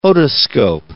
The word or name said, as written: otoscope